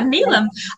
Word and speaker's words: Neelam: And like, so you talked about you have a Fijian Indian, Neelam, if I got her name right. Yeah, Neelam.